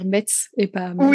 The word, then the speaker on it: Metz: Et donc, les personnes, en fait, pour montrer la résistance, l'opposition face à l'occupation, eh bien, en fait, les résistants ont commencé à appeler la ville, à prononcer la ville Metz.